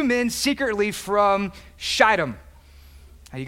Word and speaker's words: Shittim: It's Shittim, long I, from Shittim.